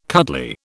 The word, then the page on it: cuddly: British English